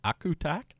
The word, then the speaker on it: Akutaq: They call it, I pronounced that right, Akutak, A-K-U-T-A-Q. And it's made by using a concoction of reindeer fat, seal oil, freshly fallen snow or water, fresh berries, and sometimes ground fish.